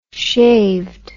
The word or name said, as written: shaved